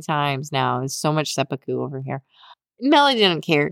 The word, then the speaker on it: Seppuku: Oh, can I even pronounce that word? Seppuku? – Seppuku. – Seppuku.